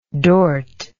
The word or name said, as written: dort